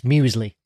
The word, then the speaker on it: muesli: First up on the menu is Anthony's breakfast, which is Alpen brand muesli. Did I say it right? – Yeah, it's a soft S, muesli.